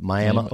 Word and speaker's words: Miami: Miami, it's spelled like Miami, but Miami, Oklahoma.